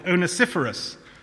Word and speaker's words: Onesiphorus: We have a man called Onesiphorus.